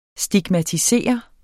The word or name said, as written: STIGMATISER